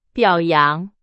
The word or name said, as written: 表扬